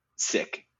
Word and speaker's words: Sikh: Joe, to your pun, the British pronounce it sick.